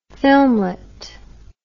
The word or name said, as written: filmlet